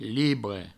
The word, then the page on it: libre: provençal